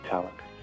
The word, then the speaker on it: Tallack: His last name is spelled T-A-L-L-U-C-K. Am I mispronouncing it, Malachy? – No, that's right, Malachy Tallack.